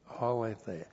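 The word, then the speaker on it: aletheia (ἀλήθεια): And aletheia, A-L, long E-T-H-E-I-A, aletheia.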